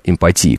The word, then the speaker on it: эмпатии: Здесь речь идет о том, эмпатии, кстати, правильно говорить, речь идет о том, что они думают, что они и Немцов это одно и то же.